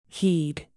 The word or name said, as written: heed